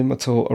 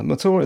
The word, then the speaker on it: Mathur: The show that you just listened to is presented, produced, edited, made, fretted over, worried over, obsessed over by me, Roy Matur, a writer. Matur is spelt M-A-T-H-U-R for the love of the Dark One himself.